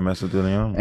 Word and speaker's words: mesothelioma: A really good friend of mine's dad passed from, how do you say, mesothelioma? – Yeah, mesothelioma.